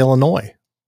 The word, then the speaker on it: Illinois: Is it Illinois or Illinois? – I think it's Illinois. I got to go with the silent S.